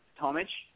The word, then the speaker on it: Tomic: If Eastern Europe, it's Tomic. Obviously, yeah. – with the Serbian Croatian pronunciation of the IC at the end, so.